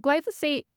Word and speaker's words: glyphosate: You've touched a little bit about glyphosate. Am I saying that right? Talk mm-hmm. about that. Like, what is it? What does it do? Why is it bad? – So glyphosate, it's not the worst herbicide.